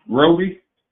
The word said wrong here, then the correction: Ryobi: Oh, oh, oh, oh, the brand Ryobi, Re, Re, Ryobi, yeah.